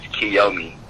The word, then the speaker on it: Kiyomi: Is it called Kiyomi? K-I-Y-O-M-I, Kiyomi.